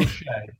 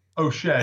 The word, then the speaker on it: O'Shea: O'Shea. – O'Shea? – O'Shea, yeah.